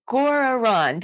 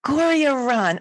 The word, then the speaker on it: Gorriaran: So I have Arjuna Solutions with us today, more specifically Gorriaran. Did I say that right? How'd I do? – It's pretty good. It's Gorriaran. – Gorriaran.